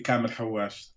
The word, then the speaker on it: Kamel Hawwash: It's Kamel, and I think you pronounced it correctly, Kamel Hawash.